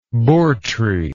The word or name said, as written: bourtree